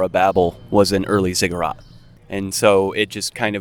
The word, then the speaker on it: Ziggurat: Why don't we talk about your first EP, Ziggurat? – Yes. – I said it right? – Mm-hmm.